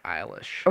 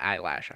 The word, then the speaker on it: Eilish: name is Eilish. – Okay. – Is not Eyelash.